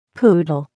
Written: poodle

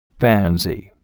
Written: pansy

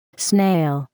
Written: snail

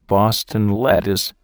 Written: Boston lettuce

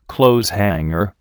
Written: clothes hanger

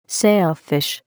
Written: sailfish

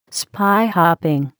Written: spyhopping

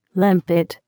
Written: limpet

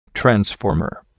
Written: transformer

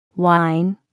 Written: wine

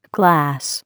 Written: glass

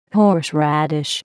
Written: horseradish

